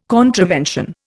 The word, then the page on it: contravention: Indian